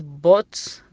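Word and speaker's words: put: حالات شاذة تكتب اليو وتنطق بالضمة اليو، مثل كلمة بوت، بوت ذا جلاس بليز، بوت ذا جلاس، بوت ذا جلاس داون، بوت تنطق ب بعدها يو، تنطق بحرف الضمة كأننا حنا ضمينا حرف البي، بو بوت.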